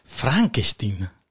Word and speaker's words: Frankenstein: Dottor Frankenstein? – Frankenstein? – Vuol prendermi in giro? – No, si pronuncia Frankenstein.